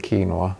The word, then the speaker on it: quinoa: Den kallas ju, quinoa är ju då ett, eller man borde kanske uttala det quinoa egentligen, det är ett spanskt namn.